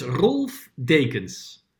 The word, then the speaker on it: Rolf Dekens: Yes, my name is Rolf Dekens. Well, that's how I say it. In Holland you say it, yeah, like Rolf. It's a German name actually, but I live in the Netherlands.